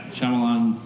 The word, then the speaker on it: Shyamalan: Night Shyamalan or something, you know? Yeah, Shyamalan.